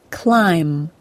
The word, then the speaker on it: climb: Today I'm going to talk about the silent B in the MB spelling, like the word climb, C-L-I-M-B.